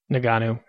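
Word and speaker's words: Ngannou: How do you pronounce it again? Naganou? – Naganou. – I keep saying it wrong.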